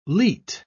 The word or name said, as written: leet